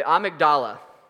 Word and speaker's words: amygdala: And I actually had Google say that to me, so I was saying it right. The, the amygdala.